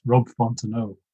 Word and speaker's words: Fontenot: I think I've referred to him as Rob Fontenot before, but it's Rob Fontenot. – Yeah, I guess it would be that French silent T.